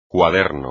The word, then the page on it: cuaderno: espagnol